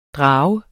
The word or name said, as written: drager